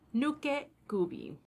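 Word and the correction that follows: Nukekubi: right? – Nuke kubi, yeah, that's the yeah.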